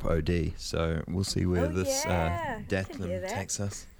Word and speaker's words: DAHTM: Now, this next band you've selected for us, is it, how do I pronounce it, D-A-H-T-M? – I believe it's pronounced Datum.